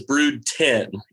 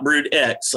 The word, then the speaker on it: Brood X: Is brood 10. It's called brood 10, not brood X.